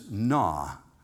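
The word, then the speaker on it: na (נא): In the Hebrew language, the word is na. If you were looking at it phonetically, it'd be N-A-W.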